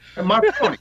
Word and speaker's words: Marconi: I'm going to hang out with the fellas at Marcone's. – Marconi. Try to pronounce the last vowel so you don't sound like the Sopranos guy.